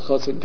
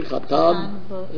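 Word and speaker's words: Chatan (חתן): Actually, in Hebrew, I mean, if you use it the Sephardi pronunciation, then it would be, you know, not Chasan Kala, but it would be Chatan and Kala.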